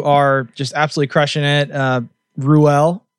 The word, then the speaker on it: Ruel: How many different wrong pronunciations have you got? – Oh, there's so many. I think Rule is the most popular, though. – Rule. So not Rule. That's like the Mississippi pronunciation.